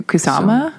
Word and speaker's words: Kusama: Kusama? – Yeah.